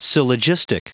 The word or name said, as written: syllogistic